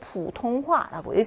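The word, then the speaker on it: 부통화라고 (普通话): 중국의 표준어는 부통화라고 하죠. 보통화고 부통화라고 읽습니다.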